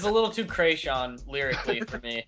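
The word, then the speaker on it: Kreayshawn: Well, it's Krayshawn, but it's supposed to be a play on creation.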